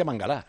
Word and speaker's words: Mangalá: Mangalá, porque se pronuncia Mangalá, lo que le llamamos todo Mangalá.